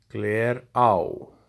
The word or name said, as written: Glerá